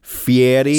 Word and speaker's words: Fieri: Fieri. – Fieri. – So I've heard it. – Yes, you said it right. You said it right, but I can't do it. – Fieri. – It's Italian.